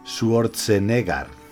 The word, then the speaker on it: Schwarzenegger: Bueno, el nombre, el nombre se pronuncia Arnold Schwarzenegger.